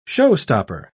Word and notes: show-stopper: British English

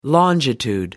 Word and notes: longitude: American English

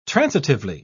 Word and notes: transitively: British English

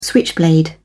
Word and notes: switchblade: British English

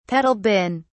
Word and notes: pedal bin: British English

